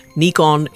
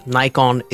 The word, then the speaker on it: Nikon: And just as an aside, Nikon is right, Nikon is right, Niko is also right.